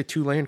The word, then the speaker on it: Tulane: Did I say Tulane correct? – Yeah, they got.